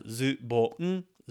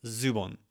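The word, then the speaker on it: zubon (ズボン): Look at the katakana and then say it like it would be pronounced in their language, not ours, okay? And that counts for words that may not be in your native language. For example, the word for pants in Japanese, zubon. I don't know how to say it in French, but I still pronounce zubon, zubon in Japanese for pants, okay?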